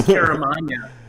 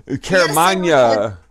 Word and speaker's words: Caramagna: Caramanga. Nah. – Close. I think it's Caramagna. – Caramagna. Yes, Italian. – Yeah, like, Caramagna. You need mayonnaise.